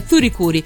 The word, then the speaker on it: FLCL: scritta così, ma la pronuncia è Furikuri.